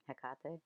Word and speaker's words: Hecate: I'm not going to say her name right. Hecate? – Hecate, yeah.